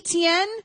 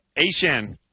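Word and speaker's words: Achane: And so apparently, A-Chan, who was the artist formerly known as ETN, says that it's always actually been A-Chan, but we've always been pronouncing it wrong with ETN.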